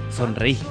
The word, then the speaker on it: Sonreí: Estamos escuchando a los muchachos de Sonreí o Sonrey. La verdad que nunca supe cómo se le dice a los chicos. – Sonreí. – Sonreí, con acento en la I al final, decís vos. – Sí, con acento.